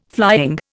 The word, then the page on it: flying: Indian